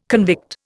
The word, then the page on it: convict: Indian